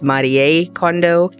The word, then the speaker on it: Marie Kondo: Yes, that is how you actually pronounce her name. It's not Marie, it's Marie Kondo.